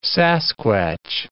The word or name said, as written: Sasquatch